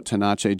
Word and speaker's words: Tenace: And how about Gene Tennis, Raymond Jeannot Tenace? He used to say his name the right way. Raimondo Gino Tenace.